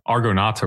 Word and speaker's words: Argonauta: the The record is out on uh, Argonauta Records.